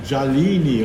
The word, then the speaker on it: Jalini: I'm here with Jelina? – Jalini. – Jalini. Oh, sorry. I mispronounced your name.